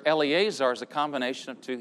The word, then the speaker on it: Eleazar: But Eleazar. Eleazar is the Hebrew pronunciation of the New Testament name Lazarus.